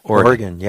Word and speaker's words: Oregon: That's the correct pronunciation, you know. – Oregon, yes. Not Oregon.